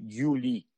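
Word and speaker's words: Yuli (Юлий): Esa letra significa Yu, o sea, por tanto, suena Yu. Así se escribe Yuli.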